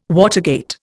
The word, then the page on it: watergate: Indian